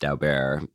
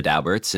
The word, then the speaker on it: Daubert: In 1993, the Supreme Court decided this decision called Daubert, you know, and I, even though it looks like it should be pronounced Daubert, I know people who know the Dauberts and that's how they pronounce it.